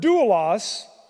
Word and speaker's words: doulos: Doulos is spelled D-O-U-L-O-S, okay? I won't give you the Greek word letters, but that's the English letters, doulos.